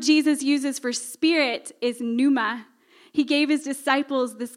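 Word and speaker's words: pneuma (πνεῦμα): In the New Testament, the word spirit is pneuma. The P is silent there.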